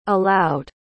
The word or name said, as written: allowed